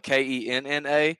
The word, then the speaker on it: Kenna: that game, yeah, it's a uh, it's spelled K E N N A, but it, it's it's pronounced Kenna.